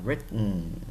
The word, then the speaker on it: written: Had written, bạn thích written cũng được, nhưng mà người Mỹ họ đọc là written, giống như chữ didn't hồi nãy á. Okay. Họ đọc written, xong rồi cái họ nuốt âm, còn có âm giống như mình vậy đó, written.